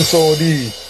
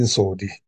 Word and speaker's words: Sodi: What actually is ironic about our real-life godfather is that his affairs are sordid, which rhymes with his name. Let us track the similarities between Vito Corleone and Edwin Sodi.